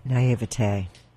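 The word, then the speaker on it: naïveté: Now, explain to us a bit of what demonology is, because I guess in my naivety, how do you say that? – Naivete.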